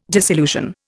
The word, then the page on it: disillusion: Indian